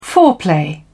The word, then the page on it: foreplay: British English